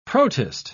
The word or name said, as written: protist